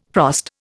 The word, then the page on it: frost: Indian